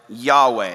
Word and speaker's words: Yahweh: And theologians think the most likely pronunciation would have been something like Yahweh. And that's why we say the name Yahweh.